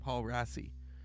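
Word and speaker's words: Paul Raci: Paul Racy.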